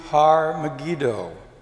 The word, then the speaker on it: Har Megiddo: It is pronounced in Hebrew, Har Megiddo.